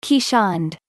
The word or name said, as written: Keeshond